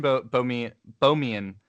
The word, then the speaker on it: Bohmian: And then, so you mentioned Bohmian Is that how it's pronounced? Is yeah, it... Bohemian Mechanics. Okay. It's named after a guy named Bohm. Has nothing to do with Bohemia.